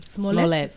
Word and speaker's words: Smollett: And then Jesse, how do you say that damn last name? – Smollett. – Smollett. Is it French? – Smollett.